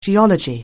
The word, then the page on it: geology: anglais